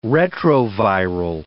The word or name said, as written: retroviral